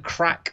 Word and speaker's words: craic: Yeah, so David Dunn and Gary Flitcroft, they said they rediscovered the crack of the trade. Is it crack? I don't know how to pronounce that. The C-R-A-I-C. – Crack, yeah. – Crack, because it's the Irish way of, yeah.